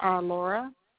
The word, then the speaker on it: Laura: Laura. – Spell it for me. – Pardon me? – Spell it for me. – L-A-U-R-A.